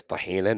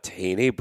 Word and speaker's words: tahina: Just out of curiosity, do you guys add, we call it tahina. Our pronunciation of it is tahina, not tahini, but yeah, tahini is completely American and I butchered it.